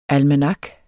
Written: almanak

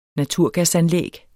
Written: naturgasnet

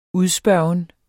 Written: udspørgen

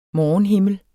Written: morgenhimmel

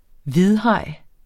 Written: hvidhaj

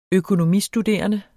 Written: økonomistuderende

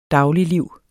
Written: dagligliv

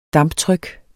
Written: damptryk

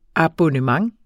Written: abonnement

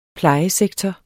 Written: plejesektor

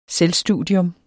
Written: selvstudium

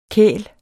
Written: kæl

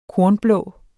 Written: kornblå

